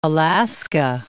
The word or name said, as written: Alaska